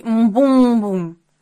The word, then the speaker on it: Мбум-бум: Родом он из джунглей Мбум-бум. Ударение на 3-м слоге от конца.